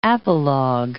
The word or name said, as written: apologue